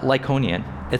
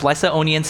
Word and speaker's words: Lycaonian: It's Lycaonian. It's not Lyconian. It's pronounced Lycaonian cities of Lystra and Derbe and to the surrounding country where they continued to preach the gospel.